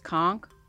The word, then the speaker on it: conch: Honestly, on the islands, we pronounce it conch. Technically, it's conch. But conch? On the islands, that's like what they say.